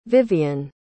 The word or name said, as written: vivian